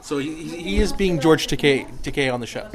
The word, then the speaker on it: Takei: No, it's Takei. Those closest to him know how he likes it to be pronounced.